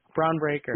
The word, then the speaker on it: Bron Breakker: Ron Breaker, JD McDuff, and I can't pronounce the other guy. – Bron Breakker.